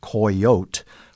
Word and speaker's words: coyotl: It's from the Nahuatl language, and the original pronunciation had been spelled C-O-Y-O-T-L, and in that language it's pronounced coyote with a silent L.